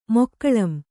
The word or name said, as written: ಮೊಕ್ಕಳಂ